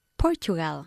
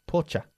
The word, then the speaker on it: Portugal: ¿Cómo pronunciamos el país Portugal? – Portugal. – Portugal. Pocha. – Pocha. – Portugal. ¿Cómo se dice futuro? – Future. – Future. Cha. Como C-H-A.